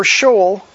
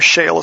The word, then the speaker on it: Sheol: Death shall be their shepherd, and the upright shall rule over them in the morning, and their form shall be for Sheol to continue, or Sheol as some pronounce it.